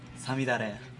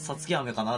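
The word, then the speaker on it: 五月雨: さみだれ。 – おお、いや、原稿を見せられないとパッと言われたらわからないですよ。 – 5 月雨と書いてさみだれと読むんですよ。 – なんかさつき雨かなって今普通に僕考えてましたよ。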